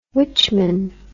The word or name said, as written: witchman